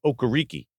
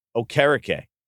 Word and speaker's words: Okereke: So on Monday Night Football, I believe it was last year or Sunday night, whatever it was, when the player introduces himself, he says Okereke. But at that point, he had already told everyone it's not pronounced that way. It's Okereke. And they're like, hey, why did you say it that way on the national telecast?